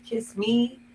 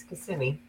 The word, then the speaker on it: Kissimmee: But my dad, we stayed in a hotel that had a sunken bar, and my dad kept trying to ask the bartender, how do you say it? Is it kiss me? Kiss a me? And I'm like, so now that I've lived here for, you know, almost 30 years, it's Kissimmee, and that's it.